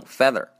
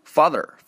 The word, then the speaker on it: father: 还有一个哦，这还有一个单词，大勾要特意啊单另拿出来说一下，就是这个“爸爸”这个单词 “father”，“father” 这个单词呢，它不会说把这个 t 哎省掉，不会说有啊啊不会还是把省略 t 去了，错了错了，它不会说把这个读成一个 a 的音，不会读成一个 feather，feather 那就变成羽毛了啊啊！这个无论是英式还有美式，他们都不会把这个。读成 feather 啊，它一定会美国人会读，把后面的儿化音还是比较强啊，可能会读 father father，但是英国人会读成 father father father，就发了 the 的音 the 的音，这个是比较明显的。